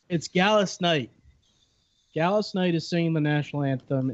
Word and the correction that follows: Gladys Knight: It's Gladys Knight.